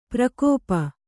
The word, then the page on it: ಪ್ರಕೋಪ: prakōpa